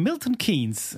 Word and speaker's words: Milton Keynes: from Milton Keynes. Am I pronouncing that correctly? – Yeah.